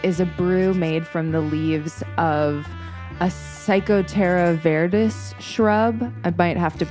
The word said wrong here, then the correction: Psychotria viridis: Psychotria viridis.